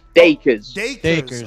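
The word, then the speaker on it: Dacres: We've been definitely getting wind of, the media campaign that you're doing for your new heavyweight, Solomon Dakari's. Am I pronouncing that correct? – Dakers. – Oh, my God. We were killing that all morning. Dakers.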